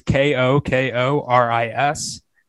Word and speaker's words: Kokoris: Uh, the, the spelling is K O K O R I S. Pronunciation is Kokoris.